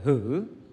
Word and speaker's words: hử: Chữ này là chữ hứ, đọc hứa, đọc là hử. Hử là một chút, còn chữ hứa là cho phép.